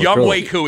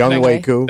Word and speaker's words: Younghoe Koo: Younghoe Koo is how you pronounce it. – Younghoe Koo.